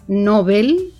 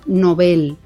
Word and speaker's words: Nobel: Recordemos que el apellido se escribe, por supuesto, con B alta y que se pronuncia siempre en agudo. No es el premio Nobel, como decimos muchos, uh-huh, muchas veces, sino el premio Nobel, ¿eh? Es una palabra aguda, y así recomiendan las academias que se pronuncie, ¿no? Respetando la pronunciación sueca del apellido de Alfred Nobel, ¿verdad? – Nobel.